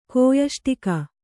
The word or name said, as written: ಕೋಯಷ್ಟಿಕ